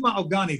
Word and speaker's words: Mahogany: I mean, I called her Mahogany for probably the first, you know, 6 months of knowing her. But then after hearing everyone else, her friends and family say her name, I realize I'm saying it wrong. And she didn't really mind. But I said, okay, if I call you Maogani.